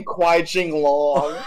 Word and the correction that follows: Qui-Gon Jinn: What? Okay, how do you pronounce what is it? What is Liam Neeson's name? – It's Qui-Gon Jinn.